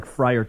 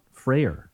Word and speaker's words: Freyer: If it was F-R-A-Y-E-R. – Right. I've always thought of it as like Friar Tuck. – Right. – But it's not. – No. – Freyer. – Yeah. – Interesting. Nobody in your family pronounces it Friar? – Absolutely not.